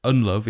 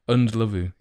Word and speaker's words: Ndlovu: Also, it's Ndlovu, is Ndlovu, is actually, everyone's been mispronouncing it for. – So I was saying it right on the videos. – Years. Ndlovu is how you say it.